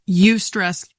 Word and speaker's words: eustress: Eustress, Y-O-U, is that what you're saying? – It's spelled E-U-S-T-R-E-S-S. – Eustress.